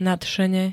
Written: nadšene

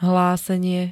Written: hlásenie

